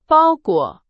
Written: 包裹

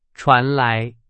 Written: 传来

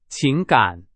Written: 情感

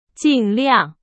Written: 尽量

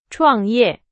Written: 创业

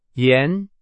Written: 盐

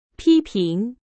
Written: 批评

